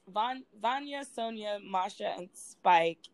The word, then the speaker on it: Vanya, Sonia, Masha, and Spike: And that leads us to the opening this week of, well, I believe I'm saying this right, Vanya, Sonia, Masha, and Spike. Am I saying it right? – You got it completely right.